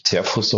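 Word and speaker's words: Zehrfuss: Und das ist ein Museum, was in den 70ern gebaut worden ist von Peter Serfus, also würde sich Zerfuss auf Deutsch aussprechen, der ein Museum in einen Hügel gebaut hat mit einer Treppe von einer Schönheit, die ich selten gesehen habe.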